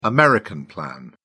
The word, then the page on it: american plan: British English